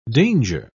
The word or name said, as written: danger